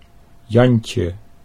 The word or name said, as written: Jantje